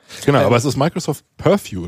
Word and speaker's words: Purview: Wie würdest du es aussprechen? – Purview. – Purview, ja genau.